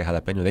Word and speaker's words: jalapeño: When completely non-Spanish speaking people say jalapeño, they pronounce it correctly. They pronounce that ñe. – Right? – They don't, no one says jalapeño. So they know that the way the ñe is supposed to be pronounced,